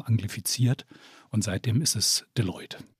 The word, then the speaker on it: Deloitte: Das schreibt sich ja französisch, spricht sich aber tatsächlich englisch-amerikanisch aus. – Deloitte.